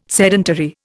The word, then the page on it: sedentary: Indian